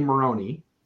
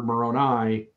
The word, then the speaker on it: Moroni: He pronounces his name Maroni. Yeah. Meanwhile, we as Mormons have the word Moroni, and that's how we pronounce it, and yet this. Well, that's just the Spanish. Yeah, the Spanish pronunciation.